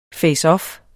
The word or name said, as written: faceoff